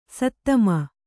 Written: ಸದುಮ

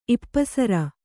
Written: ಇಪ್ಪಸರ

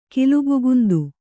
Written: ಕಿಲುಬುಗುಂದು

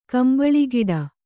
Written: ಕಂಬಳಿಗಿಡ

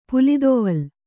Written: ಪುಲಿದೊವಲ್

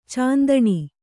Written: ಚಿಂತನೆ

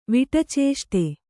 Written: ವಿಟಚೇಷ್ಟೆ

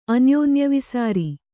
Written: ಅನ್ಯೋನ್ಯವಿಸಾರಿ

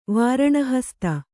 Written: ವಾರಣಹಸ್ತ

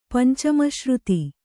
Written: ಪಂಚಮಶ್ರುತಿ

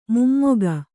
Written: ಮೊಮ್ಮಗ

